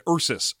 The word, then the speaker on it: Ursus: And Arsus. – And Arsus, both of whom are mispronounced in the dub. It's supposed to be Xenia and Ursus.